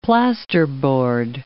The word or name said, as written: plasterboard